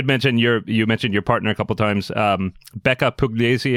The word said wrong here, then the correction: Puglisi: is name of Puglisi. – How do you pronounce that? – Puglisi.